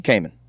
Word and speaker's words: Cayman: Cayman. – Cayman? – Like the alligator. – Oh. – It's spelled different, but that's... – Yeah, that's with a C.